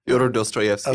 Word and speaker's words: Dostoevsky: Fyodor Dostoevsky.